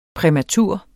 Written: præmatur

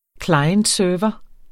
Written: klient

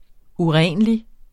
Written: urenlig

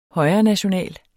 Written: højrenational

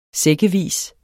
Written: sækkevis

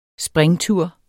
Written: springtur

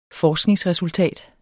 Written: FORSKNINGSRESULTAT

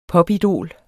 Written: popidol

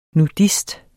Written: nudist

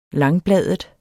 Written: langbladet

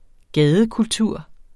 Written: gadekultur

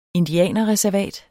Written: indianerreservat

